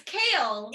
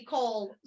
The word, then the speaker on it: Chaol: No, so it's spelled C-H-A-O-L, which is Cole. – Yes. – But it's pronounced Kale according to Sarah J. Maas.